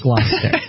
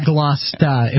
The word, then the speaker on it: Gloucester: Gloucester has an H. Gloucester. – Oh, really? – But it's pronounced Gloucester. Or Glosta if you're from Massachusetts.